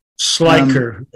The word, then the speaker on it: Schleicher: Schleicher County.